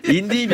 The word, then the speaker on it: YNDI: Mais j'imagine que ça se prononce en anglais. – Indie, bien sûr. – Indie.